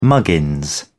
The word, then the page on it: muggins: British English